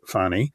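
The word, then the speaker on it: Fani: Meanwhile, old fascist Fannie, as I call her, and she likes her name pronounced Fannie, is trying to restrict the voting rights of Americans in not one, but 14 different states.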